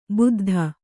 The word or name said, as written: ಬುದ್ಧ